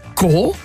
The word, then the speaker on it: corps: Il y a corps. On prononce comme C-O-R-E aussi, hein, le noyau.